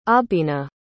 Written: aubina

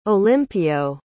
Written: Olimpio

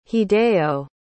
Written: hideo

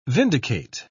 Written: vindicate

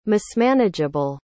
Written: mismanageable

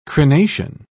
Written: crenation

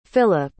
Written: Philip

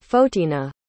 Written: Fotina

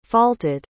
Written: faulted

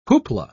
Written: whoopla

